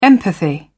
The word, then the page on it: empathy: British English